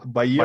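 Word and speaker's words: Bael: Причем Бейл он пишется не B-A-L-E, а B-A-E-L. Баел. Баель.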